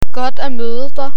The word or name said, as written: godt at møde dig